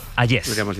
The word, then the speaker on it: Hayez: Vienes a descubrirnos el cuadro El beso de Hayez, se pronuncia, ¿no? – Hayez.